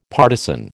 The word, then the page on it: partizan: American English